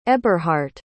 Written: eberhardt

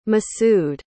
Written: masud

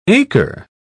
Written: acre

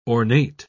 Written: ornate

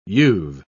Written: you've

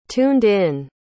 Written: tuned-in

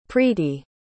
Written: preeti

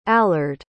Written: allard

